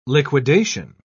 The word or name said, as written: liquidation